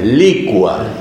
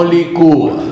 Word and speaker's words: licua: Es licua, no licúa. Licua. La gente pronuncia mal.